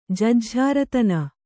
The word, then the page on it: ಜುಜ್ಝಾರತನ: jujjhāratana